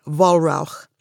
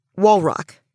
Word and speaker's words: Wallrauch: So it's like, if it was German, if you pronounced it the German way, it would be Wallrauch. Okay. It's ugly. Holy smoke is what it means. So ugly. But we say it like bedrock, Wallrauch. So pretty straightforward.